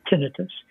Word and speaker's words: tinnitus: tinnitus is the correct way to pronounce it.